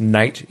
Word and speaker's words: Nate: So I'm just Nate, N-A-T-E. Rhymes with Kate is the one I've been using lately. Just think, it's not Nat. I don't know why people go N-A-T. There is an E on the end.